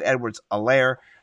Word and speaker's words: Edwards-Helaire: You know, you have, on the other hand, the man, the myth, the legend, CEH, Clyde Edwards-Helaire. The H is silent.